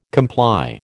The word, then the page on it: comply: American English